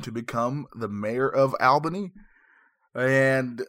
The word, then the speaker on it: Albany: Am I saying that right? I always feel like I'm saying Albany wrong. – No, you got it. It's Albany. – Sweet. – Right. Actually, how it's not. It's Albany. You? So anyone you talk to, friends, family, and you refer to it, please pronounce it correctly and say Albany.